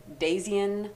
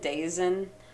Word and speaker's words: Daizien: And I'm saying Dazian, I think it is. It's D-A-I-Z-I-E-N. But if I mispronounce it at some points, please forgive me. It is going to come up a lot. So, but just know that it is spelled D-A-I-Z-I-E-N. So I think it's Dazian, but I'm not 100% sure.